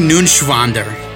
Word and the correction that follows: Neuenschwander: It's N-E-U-E-N. It's Neuenschwander. Anthony Neuenschwander.